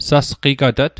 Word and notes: sasqigatat: Mi'gmaq

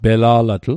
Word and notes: pela'latl: Mi'gmaq

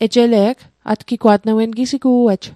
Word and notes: atgigwa't: Mi'gmaq

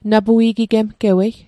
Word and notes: napui'gigemgewei: Mi'gmaq

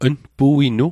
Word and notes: npuinu: Mi'gmaq

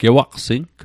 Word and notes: gewaqsing: Mi'gmaq

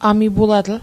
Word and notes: amipulatl: Mi'gmaq

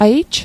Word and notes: aij: Mi'gmaq

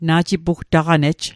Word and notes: na'jipugtaq'nej: Mi'gmaq

